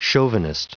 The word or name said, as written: chauvinist